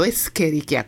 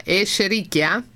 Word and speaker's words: Escherichia: Esatto, no, tra l'altro ho preso atto definitivamente che ho sempre sbagliato la pronuncia, l'ho sempre chiamato Escherichia coli e invece professionalmente il dottor Salonia lo chiama Escherichia. – Andrea. – Però attenzione, questa è la pronuncia che mi è stata insegnata. La maggior parte dei ricercatori, di chi si occupa di questo, continua a chiamarle Escherichia coli.